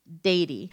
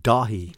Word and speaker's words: Daithí: D-A-I-T-H-I-F-A-D-A. Dathy. – I'm going with a silent H. So I'm going to say Dady. – Maybe we should do the silent T, though, from before. – Dahi. – Dahi? Dahi? Okay, what is it? – The closest with Dahi.